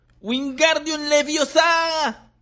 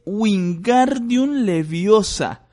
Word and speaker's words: Wingardium Leviosa: ¡Wingardium Leviosa! gritó agitando sus largos brazos como un molino. ¡Lo estás haciendo mal! Harry oyó que Hermione lo reñía. Es Wingardium Leviosa, pronuncia gar más claro y más largo.